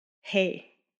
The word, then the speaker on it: He: So now we've come to the letter Hey. It's an H, H-E, and it's pronounced Hey, like, you know, H-E-Y.